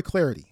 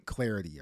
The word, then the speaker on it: Clardy: And if you haven't given me the follow on Twitter, hey, you can do that too, at Troy Clarity. Last name is spelled C-L-A-R-D-Y, at Troy Clarity.